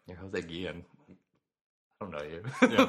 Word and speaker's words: Guillen: Jose Guillen, I'm probably mispronouncing that. – I think it's probably Guillen. – Guillen.